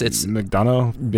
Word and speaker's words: McDonough: How did you pronounce it? It sounded good when you pronounced it. – Neil McDonough? – Sure. Yeah, it's McDonough? Yeah. – N-O-U-G-H.